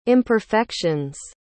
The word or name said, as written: imperfections